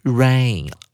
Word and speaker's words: reign: 而后面的动词 reign。 R E I G N，它的发音非常特别哦。它的 G N G 是不发音的，而 E I 念长母音，听起来就跟下雨 rain 这个字的发音完全一模一样。